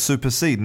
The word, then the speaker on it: supersede: So super, like Superman, and seed is spelt S-E-D-E. Seed, which is different from seed, S-E-E-D, like, you know, for a seed from a plant or a fruit. So it's supersede.